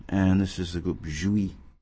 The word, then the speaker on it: Jouis: And they're called, the group's name is spelled J-O-U-I-S, and it's pronounced Jouy, like jouissance, right? And we're going to hear a track from an album called called Mind Bond, B-A-H-N.